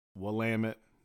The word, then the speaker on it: Willamette: It is that's how you remember to pronounce it. It's not Willamette, it's Willamette, damn it.